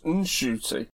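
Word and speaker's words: Ncuti: I either heard it on the radio or something, that he's been telling everyone it's pronounced Shooty. And his mum rang him and said, you've never pronounced your name right. It's pronounced N-Shooty.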